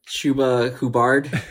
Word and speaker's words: Chuba Hubbard: Chubba Hubba is it? – . – Chubba Hubba is it? Jamar Chase, he's back. – That's a Chuba Hubbard. – Oh, dude, that's how it's pronounced.